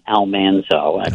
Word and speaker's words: Almanzo: She and Al Manzo, since that Dean Butler's character from the series, that it's more pleasing to the ear to hear Almanzo, but it was apparently actually pronounced Almanzo, that they were very poor.